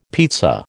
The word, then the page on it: pizza: American English